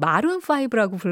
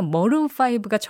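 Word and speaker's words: Maroon 5: 사실은 우리가 항상 마룬5라고 불렀는데 배철수 DJ가 머룬5가 정확한 발음이다.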